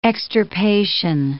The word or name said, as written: extirpation